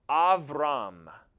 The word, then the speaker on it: Abram: His name would have been pronounced Avram.